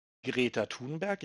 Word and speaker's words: Greta Thunberg: Ich denke, das berühmteste Beispiel dafür ist Greta Thunberg.